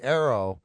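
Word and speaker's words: Eero: and I didn't get a chance to see Arrow. It's E-E-R-O, which I believe his first name is pronounced Arrow. – Correct.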